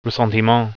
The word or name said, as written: ressentiment